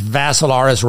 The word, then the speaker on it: Vassilaris: So Dimitri's with three I's, D-I-M-I-T-R-I. Last name is Vassilaris, rhymes with Assilaris, but you add a V to it.